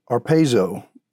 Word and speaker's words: harpazo: But as we read through the New Testament, we also see a word that's called arpazo. And in the Greek, it's spelled H-A-R-P-A-Z-O. When we translate to the English, arpazo.